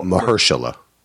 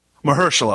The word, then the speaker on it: Mahershala: And, you know, of course, Marisala Ali? Is that how you pronounce Mahershala. – Mahershala Ali. Yep.